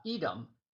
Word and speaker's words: Edom: And there's another way of pronouncing Adam. It's spelt the same way, but it's pronounced differently. Edom, and it means red.